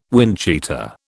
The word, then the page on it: windcheater: British English